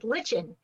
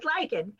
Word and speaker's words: lichen: You know, of course, people think it's lichen, but it's lichen.